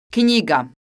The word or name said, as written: knjiga